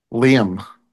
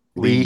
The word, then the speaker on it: Liam: You say one syllable? – That's two syllables. Liam. Liam.